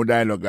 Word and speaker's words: dialogue: Comme n'a pas mis des accents, sous bel accent en anglais, sous belle prononciation en anglais, le mot dialogue a gagné deux syllabes là-dedans. Daily Dialogue, alors, il y a pesé plus sous premier syllabe là.